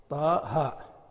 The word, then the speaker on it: طه: طه حتى ولو كانت علما على شخص فإنهم يكتبونها هكذا ولا تكتب، ولا تكتب طه، مع أن هذا نطقها طه،